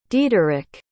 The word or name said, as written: diederich